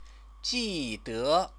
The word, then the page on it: jide: chinese